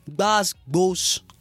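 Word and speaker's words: gbas gbos: It's spelled G-B-A-S space G-B-O-S. Basbos. So there's emphasis on the GB that you have to like sound it out. B. You know what I mean?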